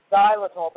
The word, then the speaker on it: xylitol: It's pronounced xylitol, but it starts with XY, xylitol, XYL. I-T-O-L, something like that.